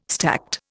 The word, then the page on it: stacte: Indian